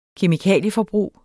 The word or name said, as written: kemikalieforbrug